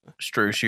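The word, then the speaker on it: Strus: But Max Strauss, I think that's how you say it, Max Strauss. – Strauss, you had it, Strauss.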